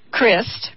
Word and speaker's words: Christ: I have to correct myself because her name is pronounced Christ, Carol Christ.